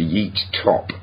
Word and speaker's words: Yiğit Top: And also we spoke to Yiğit Top. It's spelled Yiğit, but it's pronounced Yiğit Top.